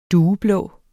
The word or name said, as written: dueblå